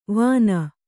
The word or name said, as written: ವಿನಾ